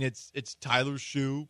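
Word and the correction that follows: Shough: probably. – Shuck. – Exactly. – Doesn't matter. – Exactly. – It rhymes with suck.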